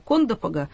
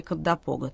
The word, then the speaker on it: Кондопога: Значит, про Кондопу и Кандапогу скажу, что местные говорят Кондопога, но новейшие словари дают правильное ударение Кандапога.